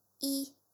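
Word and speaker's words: yi (一): So 1 in Chinese is yi. It's really easy. Yi.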